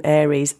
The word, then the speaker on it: Ares: We've had a point of order from a loyal but cheeky listener who's brought me up on my pronunciation of Ares. He's the Greek god of war, that's why you'd call him Ares. I still maintain if it's called A-R-E-S, it's an anagram of arse, why would you put that as your film title?